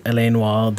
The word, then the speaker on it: L.A. Noire: Det har jo lenge vært rykter om at L.A. Noir skal komme som på ny. – Ja. – Og nå er det uttales La Noir. – Ja, La Noir.